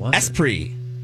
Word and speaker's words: Esprit: The answer is Esprit. – Oh, Esprit. – Esprit. Thank you. There we go. – E-S-P-R-I? – R-I. E-S-P-R-I-T is how it's spelled. Esprit.